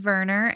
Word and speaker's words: Werner: So are we committing to Werner and not Werner? – Yeah, so it is Werner, and it's Fennig.